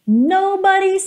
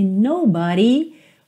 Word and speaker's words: nobody: Olha que interessante, aqui nobody, uma pronúncia típica do inglês americano, porque no britânico seria nobody, nobody, ok? Então não tem aquele d, tem o rarara.